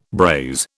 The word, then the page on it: braise: British English